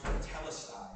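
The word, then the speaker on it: tetelestai (τετέλεσται): John uses this term, a Greek term that's pronounced tetelestai.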